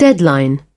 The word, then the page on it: deadline: British English